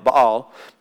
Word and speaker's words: Baal: Many people pronounce it Baal, but Baal.